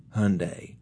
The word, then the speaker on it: Hyundai: You know, early on, there were a lot of commercials say, how do you say Hyundai? Well, it rhymes with Sunday.